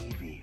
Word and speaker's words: iwi: Iwi. – Yeah, iwi. – Oh, okay. – Yeah. So it's spelled IWI. But the W sound like a V sound.